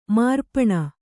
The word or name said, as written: ಮಾರ್ಪಣ